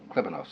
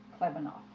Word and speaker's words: Clebanoff: Klebanoff. C-L-E-B-A-N-O-F-F. – Klebanoff.